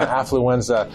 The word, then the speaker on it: affluenza: You talk a lot about affluenza in this book. I think I'm saying that right. What is that? – Yeah, affluenza.